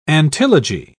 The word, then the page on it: antilogy: British English